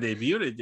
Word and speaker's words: debut: The T in debut is silent.